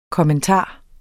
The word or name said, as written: kommentar